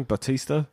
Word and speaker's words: Batista: And I was thinking, like Dave, how do you pronounce his surname? Batista? – Yes.